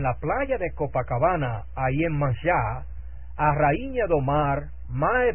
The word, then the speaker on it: Yemanjá: Los orishas del lucumí afrocubano son prácticamente los mismos allí, pues se adoran, pero con otros nombres, que por supuesto se pronuncian aportuguesadamente. Y así, Xangô llega a ser Xangô, y Yemayá es Yemanjá, etc.